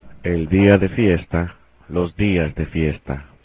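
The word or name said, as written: día de fiesta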